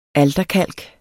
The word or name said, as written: alterkalk